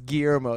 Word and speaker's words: Guillermo: In England, in Liverpool, you don't know that two L's in Spanish makes a Y sound? – No. – That's what it does. Guillermo is how it's pronounced.